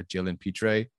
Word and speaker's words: Pitre: If I did not get someone like a Trent McDuffie early on, I would have gotten maybe like a, a Jalen Pitre. That's how you say his name from Baylor,